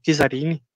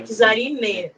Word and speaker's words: Kizarine: E já que nós entramos no assunto Kizarini, é, Kizarina, né? – É Kizarine, né? É, Kizar. Vem de Kizar, de, de Kizarina russa, mas a pronúncia é francesa, então ficou Kizarine.